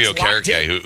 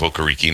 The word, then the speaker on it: Okereke: His first half of his career, he was Bobby Okereke. And then he decided, it was not pronounced that way. It was going to be, and he called himself Okereke, and then it was going to be Okereke.